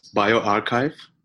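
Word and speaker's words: bioRxiv: How do you pronounce the name of that preprint server? – It's BioArchive.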